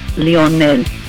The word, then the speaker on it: Lionel: You're slaughtering his name. Just say it's Leo. – Leo. – Because I know you cannot pronounce Leonel. – Leonel. – Or just say, just call him Leo.